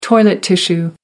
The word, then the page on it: toilet tissue: American English